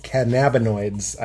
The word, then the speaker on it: cannabinoids: They're chock full of premium CBD and full spectrum of active cannabinoids. I always say that wrong. – Cannabinoids. – Cannabinoids. – Oh, really? – Cannabinoids. – I always said cannabinoids.